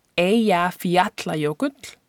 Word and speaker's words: Eyjafjallajökull: Earlier, uh, when I was talking about Icelandic nature, I mentioned a specific volcano, infamous volcano that erupted in 2010, that is pronounced Eyjafjallajökull and I thought it might be fun to teach you how to pronounce this word. This word is made up of three individual Icelandic words, the first being Eyja, which means island, Fjalla, which means mountain, and Jökull, which means glacier.